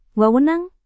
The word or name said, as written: wewenang